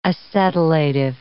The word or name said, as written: acetylative